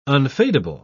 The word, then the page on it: unfadable: British English